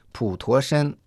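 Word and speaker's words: Путошен (普陀山): А в нашей сегодняшней передаче мы совершим виртуальное путешествие в горы Путошен. Пу произносится в третьем тоне, То читается во втором тоне, а Шен в первом тоне.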